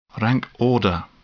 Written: rank order

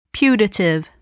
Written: putative

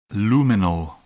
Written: luminal

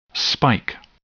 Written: spike

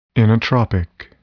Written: inotropic

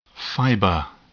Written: fiber

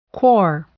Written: corps

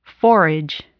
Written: forage

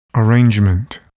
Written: arrangement

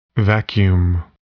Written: vacuum

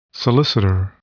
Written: solicitor

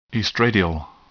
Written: estradiol

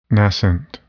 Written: nascent

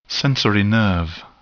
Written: sensory nerve

